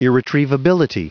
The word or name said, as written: irretrievability